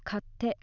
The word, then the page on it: katte: Japanese